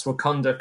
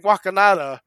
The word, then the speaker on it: Wakanda: I know I was saying Wakanada for a long time.